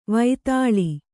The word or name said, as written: ವಿತಾಳ